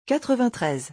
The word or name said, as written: 93